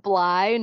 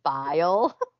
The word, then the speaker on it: Bly: I know, I was like, isn't it just Bly, not Bile? – Like, not Bile, Bly.